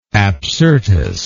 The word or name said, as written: Apsyrtus